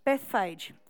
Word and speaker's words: Bethphage: Is that right? I said to him at the beginning, I was like, I forgot to ask you how you pronounce this word. I asked him about half an hour ago and I've already forgotten. What is it? Bethphage.